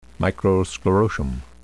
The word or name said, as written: microsclerotium